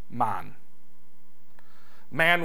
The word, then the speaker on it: mann: It was the wapend man and the weef man. Man was spelled M-A-N-N and pronounced man, man. Kind of like the Jamaicans, A-man, you know.